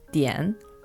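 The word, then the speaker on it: dian (点): In Chinese, this is pronounced dian, and in English, this is pronounced dot.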